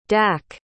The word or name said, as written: dack